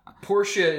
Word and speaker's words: Porcia: Portia is the correct pronunciation. – Portia, it is? Okay. – That I'm sure of.